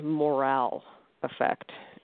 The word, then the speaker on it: moral effect: And this, it's spelled moral effect, but it's pronounced morale effect.